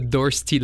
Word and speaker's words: Dorsty: So it's the Dorsty Lion. Dorsty is the symphonic Flemish take on saying thirsty.